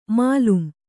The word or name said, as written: ಮಾಲುಂ